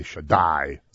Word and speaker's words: Shaddai: And when you put the accent on the last syllable, that means you're making it big, you say Shaddai.